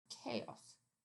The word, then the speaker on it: chaos: You don't have to call it a prefix or a suffix, but showing your student the word, let's say we have chaos. And the CH words mostly came to English from Greek, right?